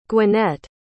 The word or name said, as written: gwinnett